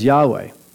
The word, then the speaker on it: Yahweh: So it's spelled YHWH, but we pronounce it as Yahweh.